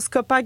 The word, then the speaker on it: Scopa: Poursuivre tout ce qui se passera pour Scopa sur Instagram, Scopa Gabbana. Ça s'écrit comme ça se dit? – Exactement.